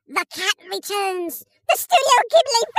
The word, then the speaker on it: Ghibli: I always say Ghibli. Like, I want to pronounce the H because I see it. Ghibli. Ghibli. Ghibli. Ghibli. Whatever. No, mate, it's Ghibli.